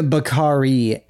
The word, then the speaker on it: Bakari: Oh, the vodka? Bacardi? – No, Bakari. – Oh, Bakari. – B-A-K-A-R-I.